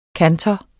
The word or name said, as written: kantor